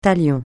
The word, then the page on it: talion: francais